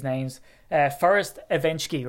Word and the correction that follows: Evashevsky: right? Oh, sorry, let me say it again. Evashevsky.